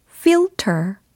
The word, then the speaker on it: filter: 필터가 아니겠죠? 필터는 영어로? filter, filter, filter. 이렇게 해주셔야 되겠습니다. F I-L-T-E-R 이라는 철자로 이루어져 있고요.